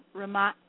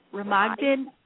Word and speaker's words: Remagen: Bridge to Ramadi. I'm going to say this wrong. Ramadan. No, it's not Ramadi. R-E-M-A-G-E-N. Ramadan. I apologize for not saying that right.